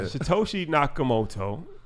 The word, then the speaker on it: Satoshi Nakamoto: Satoshi Nakamoto. Did I say that correctly? – Yeah, that was good, bro.